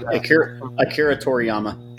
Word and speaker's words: Toriyama: He just said Akira Toriyama.